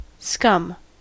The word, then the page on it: scum: American English